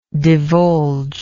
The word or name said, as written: divulger